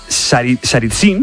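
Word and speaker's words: Tsaritsyn: Por ejemplo, Stalin convirtió, a ver si lo digo bien, Saritsyn, en bien.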